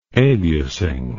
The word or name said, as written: aliasing